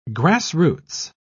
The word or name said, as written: grassroots